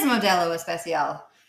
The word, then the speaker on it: Modelo Especial: Modelo Especial. – It is Modelo Especial.